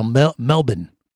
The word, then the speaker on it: Melbourne: I mean, that's, we were all along the coast around, you know, Sydney, Queensland, Melbourne, which they say Melbourne is kind of how they say it.